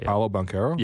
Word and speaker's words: Paolo Banchero: Can you pronounce these for me? – What, Franz Wagner and Paolo Banchero? – Yeah, perfect.